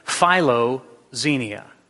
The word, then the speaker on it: philoxenia: And if you don't read Greek, I put it under there in the English phonetical pronunciation of it, philoxenia, okay?